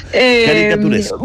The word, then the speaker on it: caricaturesco: ¿Caricaturitesco? ¿Cómo es? – Caricaturesco.